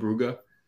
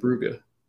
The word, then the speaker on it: Brugge: Did you know that it's called Club Bruges and it's all they pronounce it it's in Belgium, they call it Club Brugge. – Do they really? – I have really? – No idea. Yeah, it's called Club Brugge. Brugge. I was like, what? That's wild. Some cast or commentary cast, I guess, the two guys that were doing the game last time, I've never heard of either of them. They were saying, yeah, it's pronounced Brugge.